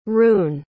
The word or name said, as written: roon